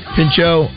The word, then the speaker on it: Pinchot: Pinchot.